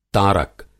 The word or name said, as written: तारक